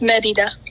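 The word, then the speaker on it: Mérida: So, being able to visit Brazil, and then you went to Merida, Mexico? – Uh-huh. – How do you say that? I'm saying it wrong. I'm so sure. – It's Merida. – Merida.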